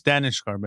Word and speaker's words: Daneshgar: Daneshgar, but it's close.